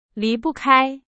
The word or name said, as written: 离不开